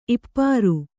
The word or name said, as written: ಈಪರಿ